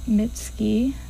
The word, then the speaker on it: Mitski: Anyway, you just heard First Love / Late Spring by Mitski, or I don't know if I said that correctly. – Yes, you did.